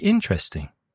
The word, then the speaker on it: interesting: So how can we make it interesting? Well, in British English, interesting is often pronounced with three syllables. Interesting. The first syllable is stressed.